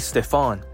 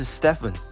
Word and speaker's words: Stephen: 再来一样，这还不是最糟糕的，简报的 Nike 代表居然没有正确发音 Curry 的名字，他叫 Curry Stefan。而正确的发音应该是 Stephen。